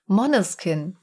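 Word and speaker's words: Måneskin: Moneskin. Wird zwar Manneskin geschrieben, aber Moneskin wird zwar anscheinend ausgesprochen. I wanna be your slave.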